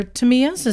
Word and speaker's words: Tamiya: And these are Tamiyas. Is that how you would pronounce it? Knock... Let me ask the Asian. – Sure. – Whoa. – It's pronounced Tamiya. – Tamiya.